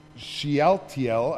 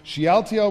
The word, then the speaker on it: Shealtiel: After the exile to Babylon, Jeconiah was the father of Shealtiel, I didn't pronounce that right. Shealtiel was the father of Zerubbabel,